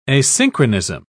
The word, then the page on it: asynchronism: British English